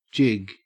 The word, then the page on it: jig: Australian English